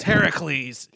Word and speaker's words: Heracles: Earlier today, you were telling me how to properly pronounce Hercules. Yeah. You got this. – It's Heracles. – Yeah, it's Heracles.